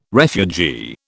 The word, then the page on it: refugee: British English